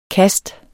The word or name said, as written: KASTENE